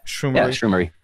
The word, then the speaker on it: Shroomery: Did I say that right? Shroomery. – Yeah, Shroomery.